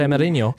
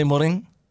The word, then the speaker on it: Mourinho: Can we just check the pronunciation on Mourinho? – Do you want to fix up your pronunciation of Mourinho, apparently? – Jose Mourinho. – Jose Mourinho. – Yeah, okay, we'll give it to him.